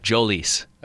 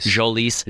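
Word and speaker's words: Jhoulys: Jolys. – I said, okay, we're close. – What are you? – Soft J. So Jolys. – Jolys Chacín.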